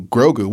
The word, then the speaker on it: Grogu: Yeah. Grogu. Yeah, Grogu. You said it right the first time.